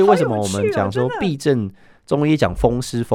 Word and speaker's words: 痹: 那在中医来讲，它是那个字念作痹嘛，是不是？ – 痹症，对，痹症。